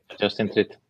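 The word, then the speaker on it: Justine Triet: Y ahí tenemos a Justin Triet por, no sé si se menciona, si se pronuncia así. ¿Sabe que ahí? – Sí, Justin Triet.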